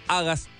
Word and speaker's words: Hagas: Alfaro se llama el disco Hagas, acento en la primera A, así lo buscas en Spotify.